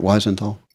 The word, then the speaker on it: Wiesenthal: And this has been reported by the Simon Wiesenthal Center. I think I'm saying his name right, Wiesenthal? – Yeah.